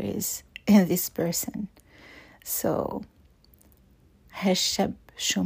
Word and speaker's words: هالشاب: In fact, the word شاب is not pronounced with a long A in the Levantine Arabic. It's pronounced with a short A, هالشاب شو معقد.